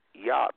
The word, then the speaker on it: Jah: J-A-H, and rejoice before him. So this is where we get the word hallelujah. We spell it J-A-H, hallelujah. But nobody say Jah. They say Yah because there was no J in the Hebrew.